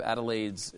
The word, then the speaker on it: Adelaide: But a new report by the University of Adelaide's Environment Institute.